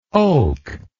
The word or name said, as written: oke